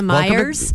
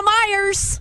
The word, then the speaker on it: Meijer: When I get greeted, when I walk into Meijer's, welcome to Meijer's. – I'm like, it's Meijer.